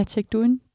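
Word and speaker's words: Tech Duinn: Tech Duin, ja, eller hvordan udtaler man det? – Ja, Tech Duin, det er, ja, perfekt udtalt.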